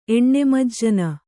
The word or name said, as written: ಎಣ್ಣೆಮಜ್ಜನ